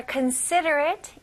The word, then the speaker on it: considerate: 마지막에 여기 ATE이지만 그냥 AT 이런 식으로 발음하시면 돼요. 자, 조금 더 빨리 considerate, considerate. 자, 강세 한번 들어보세요. Considerate. Wow, she's so considerate. You're so considerate. 네, 강세가 세 부분에 들어갑니다. 거기에 조금 더 강하게 해주면 훨씬 더 원어민스럽게 발음하실 수 있습니다.